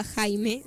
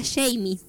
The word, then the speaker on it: Jamie: Acá me dicen, cabe destacar que la hermana de Brittany no se llamaba Jaime, ¿qué? Se llamaba Jamie, pero bueno, ah. O sea, así se pronuncia.